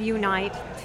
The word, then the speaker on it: Unite: My event's called Unite, which is spelled YOUNIGHT, but it's Unite to unite a community.